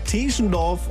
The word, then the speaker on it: Teschendorf: Heißt natürlich Teschendorf und Ziesar. Ja, aber das sind so Klassiker, die immer wieder falsch ausgesprochen werden bei uns.